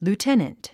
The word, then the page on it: lieutenant: luːténənt; ルーテナント